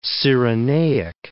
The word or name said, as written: Cyrenaic